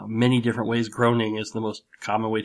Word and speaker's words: Groening: and he said Matt Groening, and then in parentheses he said, rhymes with raining. So it was, that's the correct pronunciation.